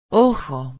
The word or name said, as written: ojo